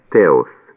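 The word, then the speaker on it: теос (θεός): По-гречески «бог» произносится «теос», пишется через «фиту».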